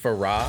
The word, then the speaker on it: Farrah: Did you know her name is actually pronounced Farrah? – Farrah. Mm-hmm. – Okay. – Nobody knew that because she never spoke.